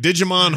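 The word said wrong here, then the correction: Djimon: It's, I don't think you pronounce the D. I think it's Jimon Hansu.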